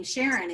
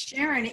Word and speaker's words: Sharon: It's spelled Sharon, but it's pronounced Sharon.